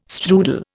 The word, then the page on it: strudel: Indian